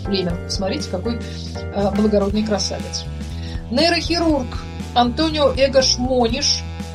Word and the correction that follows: Мониш: открывший, или Мониш, все-таки правильно ударение, португалец, открывший терапевтическое воздействие лоботомии при некоторых психических заболеваниях, даже удостоен из этого Нобелевской премии, родился 150 лет назад.